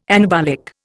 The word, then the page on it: anabolic: Indian